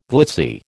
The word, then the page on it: glitzy: American English